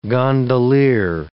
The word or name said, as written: gondolier